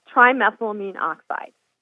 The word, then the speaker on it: trimethylamine oxide: And pronounce that again? – Trimethylamine oxide. – Trimethylamine oxide. Okay.